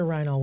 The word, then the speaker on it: Reinoehl: That's correct. It's Heather Reinoehl Whitmer.